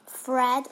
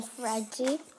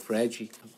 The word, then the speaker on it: Fred: He's not called Fred, he's called Fredy.